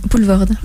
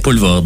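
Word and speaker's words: Poelvoorde: Puis, comment est-ce qu'on prononce son nom? – Poulvord. – Oui. Poulvord, voilà. Et un film aussi d'André Bonzel et Rémi Bellevaux.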